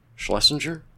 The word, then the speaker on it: Schlesinger: I think it's Schlesinger.